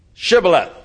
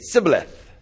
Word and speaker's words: Shibboleth: And the guys from Ephraim couldn't pronounce the SH sound. And they'd say, Sibboleth.